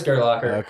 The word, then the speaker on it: Gerlacher: Yeah, it is Gerlacher.